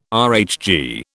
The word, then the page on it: RHG: British English